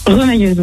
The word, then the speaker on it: remailleuse: Ça se prononce pas comme ça ? Remailleuse ? – Remailleuse, oui.